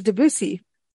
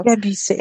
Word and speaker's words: Debussy: And we've got the composer Debussy. I knew I was going to say it wrong. That's why I shouldn't even do this show. – Debussy.